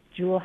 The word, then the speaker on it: Juul: Juul happens to rhyme with cool.